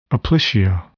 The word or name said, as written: Aplysia